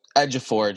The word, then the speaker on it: Ejiofor: Ejiofor, just drop the I.